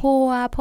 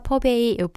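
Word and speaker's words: phở: 뭐 포와 퍼베이, 포가 이제 퍼를 갖다가 한국식으로 발음한 건데요.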